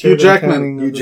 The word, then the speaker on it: Hugh Jackman: You're telling me they're going to have to animate Jack Human? – Jack Human. – Oh my god, I butchered that name. – You really did. I don't know who you were talking about. – That was Hugh Jackman.